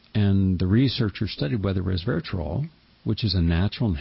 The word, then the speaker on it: resveratrol: Just phonetically, it's res, R-E-S, ver, V-E-R, atrol, A-T-R-O-L, resveratrol.